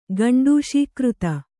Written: ಗಂಡೂಷೀಕೃತ

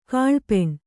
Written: ಕಲ್ಪನೆ

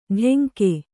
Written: ಟೆಂಕೆ

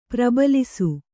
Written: ಪ್ರಬಲಿಸು

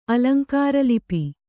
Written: ಅಲಂಕಾರಲಿಪಿ

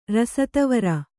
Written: ರಸತವರ

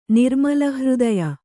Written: ನಿರ್ಮಲಹೃದಯ

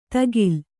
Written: ಧಗಿಲ್

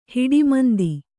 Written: ಹಿಡಿಮಂದಿ

